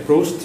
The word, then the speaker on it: Proust: hoy te voy a traer, Edgar, te voy a traer un cuestionario, se llama el cuestionario de Frost De Proust se pronuncia que es un conjunto de preguntas para reflexionar un poco.